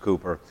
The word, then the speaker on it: Cowper: But his name is spelled like Cowper. It's pronounced Cooper.